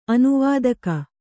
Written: ಅನುವಾದಕ

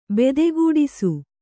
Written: ಬತಗೆಡಿಸು

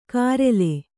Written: ಕಾರೆಲೆ

